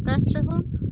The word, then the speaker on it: Castrejon: Teresa Castrejon. – Teresa? – Yes. – T-E-R-E-S-A? – C-A-S-T-R-E-J-O-N.